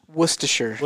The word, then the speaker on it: Worcestershire: Worcestershire.